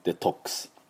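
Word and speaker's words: detox: でまずこのデトックスちょっと普通のネイティブの発音でやってみます、どうなるかというとですね、デトックス、これアメリカ人の発音ですね、 O のところめちゃくちゃ喉を開きますが、デトックス、ちょっとアに聞こえますけれども、これアじゃないんです、オです。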